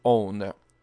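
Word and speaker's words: Ône: Alors, donc, en me disant que le cat ou le quat n'est pas du latin, que le O-N-E, donc que j'avais pris pour one, n'est pas américain, mais faut prononcer own. Donc, O accent circonflexe, N-E.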